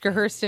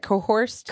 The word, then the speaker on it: coerced: So you're saying because I don't look like she does that I was just coerced into... How do you say that? Cohorsed? – Coerced.